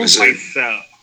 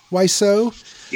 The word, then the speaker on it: Wiseau: Uh, it's Tommy uh, Wiseau. – Wiseau. – Wiseau.